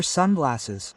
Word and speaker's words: sunglasses: These are sunglasses. Stress on the first syllable.